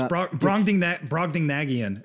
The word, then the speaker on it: Brobdingnagian: No, yeah, it's Brobdingnagian.